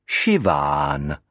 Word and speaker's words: Siobhan: V irštině se s, předcházející i nebo e, vyslovuje jako š a bh se vyslovuje jako v. A je dlouhé, takže Siobhan vyslovíte jako Šiván.